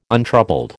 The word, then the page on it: untroubled: American English